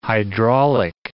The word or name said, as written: hydraulic